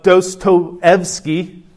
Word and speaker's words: Dostoevsky: Chapter 5 of Dostoevsky, nailed it, his novel, The Brothers Karamazov, is an updated examination of the temptation of Jesus.